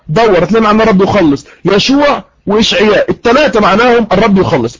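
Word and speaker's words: يشوع (ישוע): النقطه رقم 2، النقطه رقم 2، قلنا ان يسوع في العبريه تنطق يشوع.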